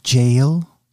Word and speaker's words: J.L.: Yeah, it's J.L. Like Jailhouse? – Yes.